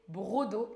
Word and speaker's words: broc d'eau: Alors ça s'écrit B-R-O-C, prononcé brodo et ça vient de broc d'eau.